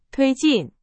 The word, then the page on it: 推进: Chinese